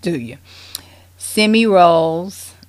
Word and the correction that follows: Semmelweis: and probably didn't pronounce that right. Y'all excuse me here. Semmelweis, because I never seen these names, but he was a Vietnamese doctor.